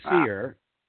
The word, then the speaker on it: Seher: I have to add, it's a she, and I always say Seer, S-E-H-E-R, Seer Khan.